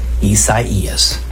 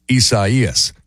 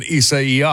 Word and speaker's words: Isaias: How do you pronounce it? Isaias. Isaias. – There you go. And that's the Spanish pronunciation. Isaias. Some people are saying Isaiah.